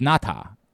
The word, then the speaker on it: Ngata: It, by the way, it bothers me to this day that we got Haloti Ngata's name wrong, and it was Ngata. And we didn't say it correctly, and we made fun of Jim Nantz because Jim Nantz would say it that way. We'd be like, well, "Listen to this dork overpronouncing the name," and then we were like, "No, that's actually how it's pronounced."